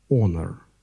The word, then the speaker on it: honor: Honor, honor. Первая буква H не произносится. Honor.